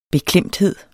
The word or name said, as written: beklemthed